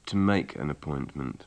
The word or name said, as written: to make an appointment